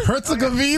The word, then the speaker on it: Herzegovina: Herzegovina? Herzegovina.